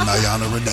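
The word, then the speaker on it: Niana Renee: That Nayana Renee. Nayana Renee. Nayana Renee. Nayana Renee. – That's N-I-A-N-A-R-E-N-E-E.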